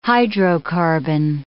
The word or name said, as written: hydrocarbon